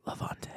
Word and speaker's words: Levante: And you have to say it like this, Levante.